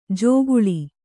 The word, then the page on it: ಜೋಗುಳಿ: jōguḷi